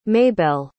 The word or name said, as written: maybell